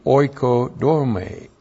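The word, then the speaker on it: oikodome (οἰκοδομή): So we went from oikia and skenos to oikodome. O-I-K-O-D-O-M, long E.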